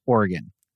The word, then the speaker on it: Oregon: But the way you pronounce that word is Oregon. – Okay. – Talk to people from Oregon. That's an O, not an R. So we're learning this week about numbers, betting, but also how to pronounce an O at the beginning of the word.